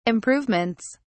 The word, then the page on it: improvements: British English